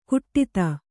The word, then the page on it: ಘಟಿತ: Kannada